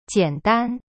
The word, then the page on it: 简单: jian dan